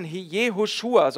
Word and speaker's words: Jehoshua: Aber mit dem Namen Jehoshua, so wird es in Hebräisch ausgesprochen, ist das noch klarer.